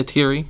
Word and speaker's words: Tieri: I'm hoping I'm saying that correctly. It looks like that's how you say that. Like Thierry, wouldn't you say? – Let's see. – T-I-E-R-I? – Yeah, Thierry. – Yeah. – T-I-E-R's Thierry.